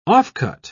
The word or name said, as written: offcut